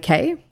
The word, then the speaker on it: Cay: So I'm going to talk to you about a woman named Kylie K. – Okay. No relation to Special K? – No, her last name is spelled C-A-Y.